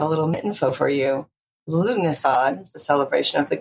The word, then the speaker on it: Lughnasadh: It's pronounced Lughnasadh, or Lammas for short. – Oh, my goodness gracious. But if you saw how it was spelled, it was like, it's like trying to pronounce a city in Massachusetts. It just, there's letters there that aren't being used. It's an interesting one for sure. – You know why? It's a Celtic word, and the Gaelic. So the Gaelic, there's letters that are pronounced differently.